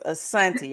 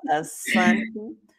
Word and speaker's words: Asanity: Okay, so it's Asanity. The first I is silent. – There it is, Asanity. Y'all saw how I jacked that up real quick, but that's her name, Asanity Foster.